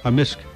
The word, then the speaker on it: amisk: Amisk. Amisk. – So the K can be silent, but in general it's...